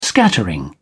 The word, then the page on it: scattering: British English